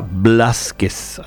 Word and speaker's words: Blázquez: Bien, como suena con B larga y con acento en la A, Eladia Blázquez. Así como Vázquez, poetisa, pero argentina.